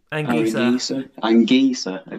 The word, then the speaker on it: Anguissa: But yeah, Aaron Gies, is that how you say it? – Anguissa, okay.